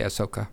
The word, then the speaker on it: Ahsoka: Yeah, but we don't really pronounce the ass. We just say Ahsoka. – Ahsoka. – Sorry, I'm 12 years old with a low voice. – The H is before the S, not after.